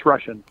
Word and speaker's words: Rushin: Steve, is it Rushin or Rushin? – It's Rushin. – It is Rushin.